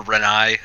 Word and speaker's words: Renai: Rose Byrne, Renee, or Renai, as it should be pronounced.